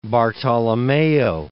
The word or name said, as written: Bartolommeo